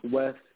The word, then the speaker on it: VVEST: Audio Mac, Apple Music, Spotify, VVEST, all caps, but pronounced West. So VVEST, the name, but not like protection, it's more like direction.